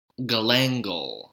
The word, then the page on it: galangal: American English